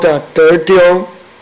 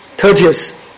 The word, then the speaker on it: Tertius: There's another guy called Tertio. How do you pronounce it? Tertio? How do you pronounce it? Tertius.